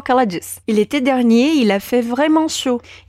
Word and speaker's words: dernier: E aqui vale a pena a gente atentar para a diferença de pronúncia entre o masculino dernier e o feminino dernière. Eu sinto que os alunos de francês sempre tendem a falar dernière para tudo. Mas quando a palavra for masculina, a gente tem que dizer dernier.